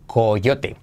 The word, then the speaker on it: coyote: En inglés se escribe coyote, pero ¿cómo se dice? Se dice coyote. No, fíjate, se dice coyote. Coyote, como cayó cayó uri. Coyote. Con, fíjate la transcripción fonética que te pongo debajo, con R eh, suave española.